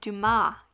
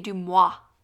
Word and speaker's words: Dumas: Well, you're already mispronouncing Dumas, so it's fine. It's Dumas. You say Dumois.